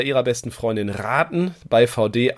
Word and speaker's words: Vaude: Heute spreche ich mit einem Unternehmen, wo sich vielleicht manche fragen, wie wird es eigentlich genau ausgesprochen? Vote oder VD? Es ist letzteres, bestehend aus dem Namen der Gründerfamilie von Dewitz und ich spreche mit Miriam Schilling.